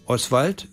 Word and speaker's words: Oswald: Oswald wird anders betont, Oswald, und Sie merken, das O wird schwach ausgesprochen und dann bleibt das Schwald übrig.